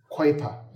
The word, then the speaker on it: Kuyper: Now, that is Abraham Kuyper. – Kuyper. – Abraham Kuyper. That's the right pronunciation.